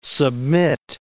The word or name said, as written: submit